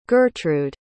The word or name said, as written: gertrude